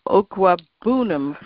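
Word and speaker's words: Okwuegbunam: Uh, Okwabunum from Missouri. Actually, that's ah. exactly how I've been pronouncing it here on Locked On Patriots.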